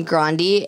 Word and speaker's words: Grande: But like Ariana Grande... pronounced her name Grande, and everyone was like, what the fuck?